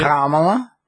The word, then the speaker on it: Kamala: Kamala? – Right.